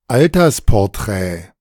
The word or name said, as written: Altersporträt